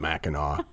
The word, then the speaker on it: Mackinac: I was really proud of myself that I remembered to pronounce it Mackinac. Our freaks in Michigan, I'm sure, will appreciate that.